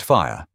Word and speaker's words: FHIR: FHIR, pronounced fire, and HSDS, and the roadmap that makes the Durham pilot a credible foundation for regional or statewide expansion when the time comes.